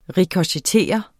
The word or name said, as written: rikochettere